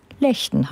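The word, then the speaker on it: Legden: Und seit mich empörte Rückmeldungen aus meinem Kollegenkreis erreicht haben, wie ich den Ort ausgesprochen habe, weiß ich auch, dass es Lechten heißt, im Kreis Borken.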